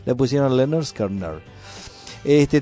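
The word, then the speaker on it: Lynyrd Skynyrd: Esto se llama Leonard Skinner. Es un grupo que se escribe todo con Y.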